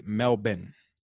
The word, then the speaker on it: Melbourne: You said Melbourne. I've been corrected by some Australians. It's Melbourne. – Yeah, it's more like Melbourne.